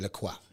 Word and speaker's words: LaCroix: Like LaCroix. French pronunciation, LaCroix.